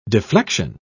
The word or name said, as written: deflection